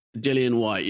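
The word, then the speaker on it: Dillian Whyte: First of all, how do you say Dillian White? Not Dillian, right? – Dillian White, yeah.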